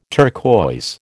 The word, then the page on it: turquoise: American English